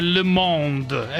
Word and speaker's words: Le Monde: Il prestigiosissimo quotidiano francese Le Monde.